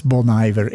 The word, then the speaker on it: Bon Iver: Auch natürlich, weil ich sofort verstanden habe, was Bon Iver ist.